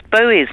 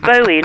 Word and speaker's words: Bowie: However, I'm amazed the amount of people that don't pronounce Bowie's name right. It's Bowie, not Bowie. – Is it Bowie? – It's Bowie, yeah, like a Bowie knife.